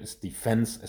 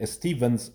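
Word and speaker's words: Stephens: این پی اچ وی تلفظ میشه من این, این رو خیلی باش کلنجار رفتم ولی به نظر میاد استیفنز استفان نباید بگی استیون هست در اصل در زبان انگلیسی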